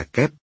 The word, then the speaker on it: giáp: Vậy chữ giáp trong quản giáp thời Lý đọc là kép, như vậy là đến thời Lý.